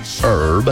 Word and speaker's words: Herb: Peaches and Herb. I want to say Herb because it's spelled H-E-R-B. But anyway, Herb is applicable.